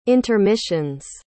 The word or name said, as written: intermissions